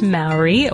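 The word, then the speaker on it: Māori: And it was brought to our attention many times by listeners that we mispronounce the word Maori. It's the name for the people who are native to the area of New Zealand, kind of the indigenous race there. And we pronounced it Maori throughout the podcast. So we have to apologize for that and correct it. It is pronounced Maori, or at least that's an acceptable pronunciation, I think, for Yanks like us.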